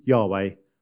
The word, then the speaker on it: Yahweh: So Yahweh is actually written YHWH, which is actually pronounced in ancient Hebrew as Yod He Now, it's anglicized to Yahweh, makes it much easier for all of us, so I think we're going to stick with that.